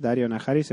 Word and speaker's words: Daario Naharis: No sé si os disteis cuenta, pero yo recién caigo que en inglés Daario Naharis se pronuncia igual que Daenerys.